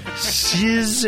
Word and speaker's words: Suze: Cizz is spelled S-U-Z-E.